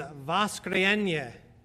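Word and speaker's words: Voskrienye (Воскресенье): Anyway, I was informed or reminded just a week or so ago that the Russian word for Sunday is Voskrienye. Anywhere near? Yeah.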